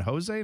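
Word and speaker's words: José: So when I first saw it, are they just not saying Jose? And it's like, no, He's Portuguese. That's how he says it. So that's how that's how he pronounces it. That's the way it's pronounced.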